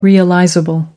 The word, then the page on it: realizable: American English